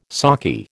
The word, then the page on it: saki: American English